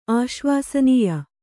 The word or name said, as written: ಆಶ್ವಾಸನೀಯ